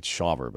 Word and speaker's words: Shaver: AJ Smith Shaver, however you say his name. It's Shaver, but that's, I've never heard that name before.